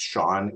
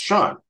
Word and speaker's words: Chaon: I thought I would let your listeners know that my last name, even though it's pronounced Shaun, is spelled C-H-A-O-N. So it's like chaos, except with an N, but it's pronounced Shaun.